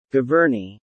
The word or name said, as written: Giverny